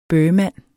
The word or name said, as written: bøhmand